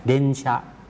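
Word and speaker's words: เด น ชะ (電車): สึครับรถไฟใต้ดินนะครับเดนชะเดนชะตรงเดนนั้นออกเสียงยาวนะครับถ้าออกเสียงช้าๆจะออกเสียงว่าเดอึนะครับแต่ออกเสียงรวบยาวก็เลยออกเสียงว่าเดนส่วนตัวชะที่อยู่ข้างหลังนั้นออกเสียงสั้นนะครับเดนชะ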